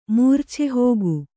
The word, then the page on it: ಮೂರ್ಛೆಹೋಗು: Kannada